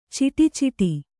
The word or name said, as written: ಛಢಾಛಡಿ